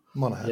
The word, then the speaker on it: Monaghan: For me, I would say it is, but that's like I say, I love Cameron Monaghan. Is that how you say his name? I think so. – Monaghan. Yeah. I think so.